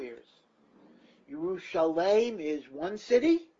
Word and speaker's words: Yerushalayim: But that is why in Tanakh, although Yerushalayim is pronounced Yerushalayim, it is always written without a yud.